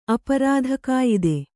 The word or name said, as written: ಅಪರಾಧಕಾಯಿದೆ